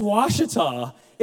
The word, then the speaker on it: Ouachita: He goes, it's O-U-A. Dad, that's Ouachita. Dad, this is not fair. Let me just apologize for English. Dad, it's not fair. That's like a fifth level word. It's wash, W, you pronounce it that way.